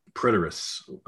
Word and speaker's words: preterists: So the first view is the preterists. I believe I'm saying that right. – Yep.